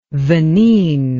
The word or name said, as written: venene